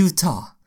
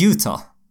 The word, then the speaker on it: Utah: De skrev ju ut det så med bokstäver, så man, så hur man ska uttala det. Då var det typ I-O-O. Ja, Utah. sen T, om något T-A-A typ.